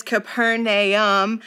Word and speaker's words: Capernaum: The official way to pronounce this town is Capernaum.